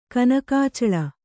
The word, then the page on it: ಕನಕಾಚಲ: kannada